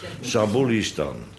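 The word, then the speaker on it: Zabulistan: Wij kennen zelfs een rijk, dat heette Sabulistan. Daar komt de klemtoon. Sabulistan.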